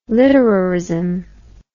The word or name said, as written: literarism